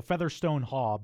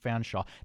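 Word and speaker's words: Featherstonehaugh: H-A-U-G-H. – H-A-U-G-H, right. So Featherstone Haw, but it's pronounced Fanshawe.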